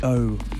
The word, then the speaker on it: Eaux: This one is new from Rrose, track called Vellum, out on her own Eaux label. E-A-U-X. Eaux, that's how you say it, right?